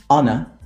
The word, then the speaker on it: honor: There are only four words in which the H is silent. And obviously the words that are either compound or that come from these words. And they are our, honor, honest, air.